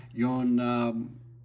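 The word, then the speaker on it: Yonebayashi: Now, this movie is directed by, and I'm going to butcher this name horribly, Hiromasa Yonabayashi? – That was good.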